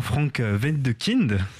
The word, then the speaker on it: Frank Wedekind: D'abord, parlons de l'éveil du printemps de l'allemand Frank Wedekind, si je prononce bien.